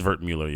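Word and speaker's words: Wertmüller: Oh, is it pronounced Wertmuller or Wertmuller? – Wertmuller, probably Wertmuller. Yeah, I'm probably doing it poorly. I mean, look, yeah, it is Wertmuller.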